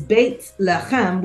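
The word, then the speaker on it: Beit Lechem (בית לחם): So it is Beit Lechem, which is how you pronounce my name in Hebrew. And it literally means house of bread.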